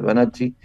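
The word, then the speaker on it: Vannacci: Resulta que hay un general que se retiró, un tal Banacci, en realidad debería pronunciar Banacci,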